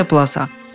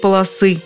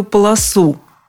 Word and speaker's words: полоса: Мы говорим, это такая полоса, черная полоса. Что делать? Да ничего, просто переждать ее и все. А чтобы не терять времени в ожидании, можно пока повторить правило ударения в слове «полоса». Есть полоса, нет полосы, на первой полосе, во всех падежах ударение на окончании.